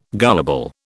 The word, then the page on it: gullible: British English